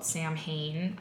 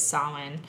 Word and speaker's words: Samhain: And, you know, in Samhain, a lot of people call it Samhain, but the pronunciation, as I've learned it, is Samhain.